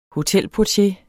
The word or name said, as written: hotelportier